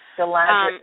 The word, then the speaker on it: Galadriel: Galadriel.